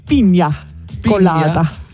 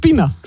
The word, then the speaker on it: Piña Colada: Ja, und wenn wir jetzt so viel davon sprechen, übrigens in Spanisch Piña Colada. – Ja, habe ich dir zu wenig Spanisch ausgesprochen? – Du hast Pina gesagt. Ja, besser gesagt Pina Colada.